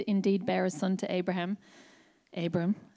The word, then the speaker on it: Abram: He was Abram. Abram. Abram. That's how you say it.